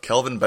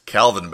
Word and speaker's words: Kelvin: persistent mispronunciation of Kelvin Benjamin, parentheses, Calvin Benjamin.